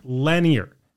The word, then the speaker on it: Lenire: Lenire, spelled L E N I R E, pronounced Lenire.